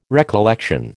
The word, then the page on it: recollection: American English